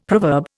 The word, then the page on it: proverb: Indian